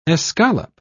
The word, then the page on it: escallop: British English